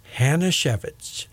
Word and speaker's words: Hajnaciewski: It rhymes with Manashevitz. Hanashevitz. H-A-J-N-A-C-I-E-W-S-K-I.